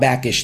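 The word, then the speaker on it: Bakish: Paramount's Bob Baskin or Backin, Backish, Bob Backish, that's his name.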